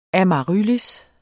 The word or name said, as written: amaryllis